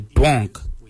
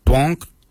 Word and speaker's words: dworng: Mil is spelled M-I-L-Y. For eyebrows, we say mimbrun. Mimbrun is spelled M-I-M-B-I-R-R-N. Mimbrun for eyebrows. For ears, we say dwonk. Dwonk is spelled D-W-O-R-N-G. Dwonk.